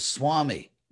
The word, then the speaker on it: Swami: ancient evidence that the Sanskrit V was pronounced like W. And uh, and we still it's still pronounced that way in certain words. Like if you think of the word Swami, where you put it's actually a V, but it's written as a W.